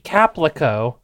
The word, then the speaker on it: Caplico: Now, these are Glicka Mini Caplico. I kept saying Calpico, which is a different product that is spelled similarly, and I've only now realized that it is, in fact, Caplico, which makes sense because this entire time I've been like, Calpico is like a soda.